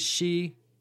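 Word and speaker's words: Xi (西): East is pronounced Dong, and west is Xi.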